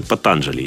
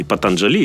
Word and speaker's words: Патанджали: И как бы, ну, если вообще обратиться к литературе, есть такой исторический документ, называется «Йога-сутры Патанджали», или «Патанджали», или «Патанджали». В принципе, в санскрите отсутствует ударение, поэтому, как не скажешь, все правильно.